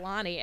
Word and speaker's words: Lani: but they look at Lonnie, L-A-N-I, and they're like, Lanny?